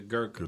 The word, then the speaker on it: Gurkha: The Gurkha.